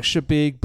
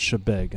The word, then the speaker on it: Chebeague: It's phonetically, it looks like Chebeague, but it's actually pronounced Chebeague, and they always correct you.